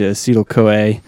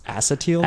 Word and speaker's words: acetyl-CoA: And the fatty acids, they can be broken down into acetyl-CoA. – I think it's actually pronounced acetyl-CoA.